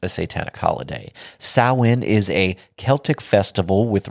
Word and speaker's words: Samhain: Or, if you want to pronounce it properly, it is Samhain.